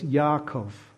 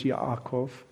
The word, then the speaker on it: Yaakov: In the Old Testament, that may not sound really familiar to you, but in the Old Testament, we have another guy whose name was Yaakov. He had a brother named Esau. In English, we say his name as Jacob.